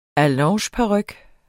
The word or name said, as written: allongeparyk